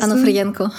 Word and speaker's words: Onofrienko: ähm, sondern du hast damals Onofrienko, habe ich das richtig ausgesprochen? – Genau, ja, Onofrienko.